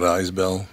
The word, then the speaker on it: Isbell: And as Rolling Stone reports, he helped shape the Americana genre, whose current star is Jason, is it Isbell or Ibell? How do you say his name? – I think it's Isbell. – I don't know. – Is it Isbell? – Isbell? – Isbell.